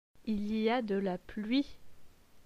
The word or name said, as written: Il y a de la pluie